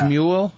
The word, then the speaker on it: Smule: One is from Smule called Ocarina. – Smule? – Smule. – How do you spell that? – S-M-U-L-E.